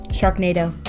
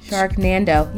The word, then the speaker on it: Sharknado: Sharknado. – I always want to say the N, like Nando. – No, I could see how you'd read it. Yeah. Sharknando.